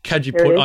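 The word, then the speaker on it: Cajeput: Kajiput, I know I've stuffed up that pronunciation. – Mandarin, no, you haven't. That's good.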